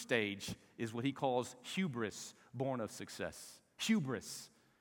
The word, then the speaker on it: hubris: It's a Greek word, hubris. It's almost pronounced the exact same way that we pronounce it in English, hubris.